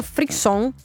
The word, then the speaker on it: frisson: E, ha un nome, gli scienziati la chiamano frisson, non so quale sia la pronuncia.